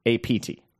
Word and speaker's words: Abt: It's spelled ABT, but pronounced APT.